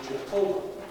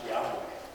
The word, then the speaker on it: Yahweh: And so they thought it was Jehovah. But more recent scholarship says that they believe it was pronounced Yahweh.